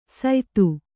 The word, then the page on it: ಸಯ್ತು: saytu